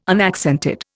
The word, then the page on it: unaccented: Indian